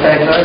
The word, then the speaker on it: Fedler: First person who has signed in to speak, and I hope I've got your name right, it's Evelyn Fedler? – Yes. – Please come forward. Thank you. – Good evening, Mr. Mayor, council members. My name is Evelyn Fedler, and I've been a citizen of Monroe for 20 years.